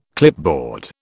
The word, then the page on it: clipboard: British English